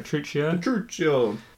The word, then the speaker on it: Petruchio: Petruchio.